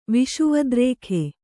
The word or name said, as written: ವಿಷುವದ್ರೇಖೆ